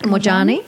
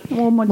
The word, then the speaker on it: Moorjani: It's Moorjani, isn't it? – Moorjani. Moorjani. Moorjani. yeah.